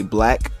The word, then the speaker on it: 6LACK: with 6 Lack, aka Black, even though it's spelled like 6 and Lack, but don't ask me why or how that even makes sense. For some reason, he went with that name and went with that spelling of the name, but pronounced it totally different from how it's spelled.